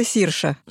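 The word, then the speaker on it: Saoirse: Так вот, ее имя пишется Сауирсе. – Ну да, да, да. – Говорится Сирша.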